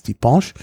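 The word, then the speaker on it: Ti' punch: Also Tipinche ist Französisch und Pariserisch ist Tipinche. – Okay. – Also das ist immer eine andere Vokalqualität. Aber wie gesagt, es ist eben aus Martinique, deshalb die lokale Aussprache ist Tipinche.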